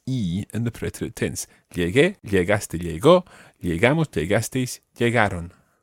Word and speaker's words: llegué: Nobody would ever say, I arrived by saying lleje. sounds really strange. You have to say llegué. So to make the G into a hard G sound, even though it's got to be followed by the E, you add in a U. It's a silent U, so it becomes llegué.